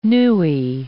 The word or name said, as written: newie